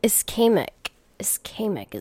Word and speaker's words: ischemic: So ischemic is the way to say it. Got it. A brief and temporary interruption of blood flow to the brain. Man, I feel like lots of people have ischemic attacks all the fucking time, all fucking day.